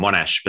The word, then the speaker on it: Monash: Monash University Paramedic Health and Wellbeing Research Unit. Did I pronounce that correctly? – Well, good, spot on, Monash, but that's everything else, spot on, thank you.